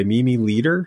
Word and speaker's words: Mimi Leder: And this episode, I mean, this episode, as another one is directed by Mimi Leder.